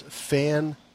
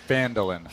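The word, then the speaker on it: Phandalin: By the way, as far as pronunciation goes, the adventure book here actually points out the correct pronunciation, and it is Fandolin. So fan is the emphasis is on fan, but it is fan, and then it's Duhlin is the three syllables.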